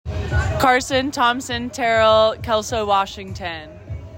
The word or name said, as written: Carson Thomson-Terrel